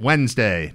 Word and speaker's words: Wednesday: I will put the N right before the D. Hear that? Wednesday. No way is that the right way to pronounce that word.